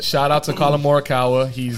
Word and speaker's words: Morikawa: Wait, can you say his name one more time? – Morikawa. – Oh, he's got it.